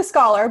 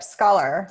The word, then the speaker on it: Scholar: It's actually scholar, but you got really close. It's like scholarship, scholar.